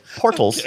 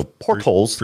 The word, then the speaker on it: portals: So, portals. Okay. I'll start pronouncing that T for you. – Okay, thank you. – I'll buy another T. So, portals.